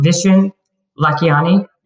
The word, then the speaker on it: Vishen Lakhiani: I don't want to say your name again if I say it wrong. Vishen Lakhiani. – You said it. – Is that right? – Yeah. – Oh, thank God.